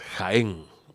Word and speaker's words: Jaén: La J se pronuncia como Y en inglés y portugués, no en castellano. Además es un apellido con tilde y las tildes no existen en inglés, así que es Jaén.